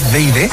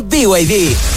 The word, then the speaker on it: BYD: ¿No es BYD? – Nada que ver, se escribe BYD, pero se dice BYD, la marca número 1 de electromovilidad en Chile y el mundo.